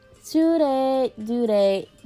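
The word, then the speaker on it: Tsurezure: It's spelled T-S-U. Zure children. Tsure dure children. Okay. Tsure dure. No. D. I'm sorry. I keep on saying Z. Tsure dure children. I keep on messing that up.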